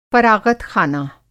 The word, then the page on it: फ़राग़तख़ाना: فراغت خانہ; Hindi